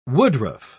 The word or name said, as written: woodruff